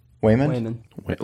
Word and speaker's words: Waymond: Waymond. – Like Raymond with a W.